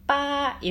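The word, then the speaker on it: 빠 (八): 그게 이제 중국어로 빠 이렇게 발음을 하는데 그 우리가 재물이 막 생기는 거 있잖아요.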